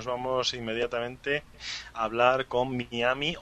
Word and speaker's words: Miami: Bueno, en inglés es Miami. En español, sería Miami, porque en español se lee, como se pronuncia como se lee.